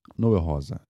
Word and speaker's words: Noel Rosa: Noel Rosa, it's the name of the guy. I'm gonna use the fucking, because it's very, it's not, you know, it's kind of stupid to use the English pronunciation of Portuguese words.